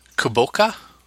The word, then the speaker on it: kabocha: Ultimately, Usagi buys a kuboka. Maybe is how it's pronounced, K-A-B-O-C-H-A, which is apparently a winter squash.